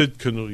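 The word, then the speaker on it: Tsidkenu: Sidkenu, yes. That's how you pronounce it.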